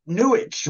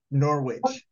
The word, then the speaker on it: Norwich: Because when I did talk to your mom, I think I pronounced it Norwich. – So. Yeah. Silent W.